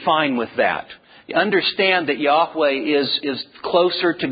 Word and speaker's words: Yahweh: Now, Jehovah then technically would be an incorrect pronunciation of God's divine name. But the fact is that even Yahweh is at best an educated guess, because all we're certain about are the four letters. The actual pronunciation of the divine name is still somewhat debated. Yahweh is probably the best and most educated guess.